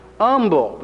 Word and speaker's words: Humble: It's pronounced Humble. The H is silent.